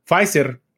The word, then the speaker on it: Pfizer: Pues Pfizer, o como lo pronuncia tu tío, P Pfizer,